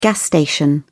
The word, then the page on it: gas station: British English